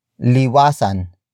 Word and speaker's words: liwasan: And that is liwasan. Liwasan. Liwasan. Liwasan is spelled as L-I-W-A-S-A-N.